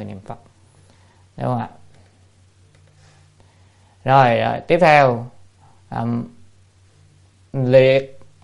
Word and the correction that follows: lệ: hay là chữ này đọc là lệ như sát giới trung thuyết.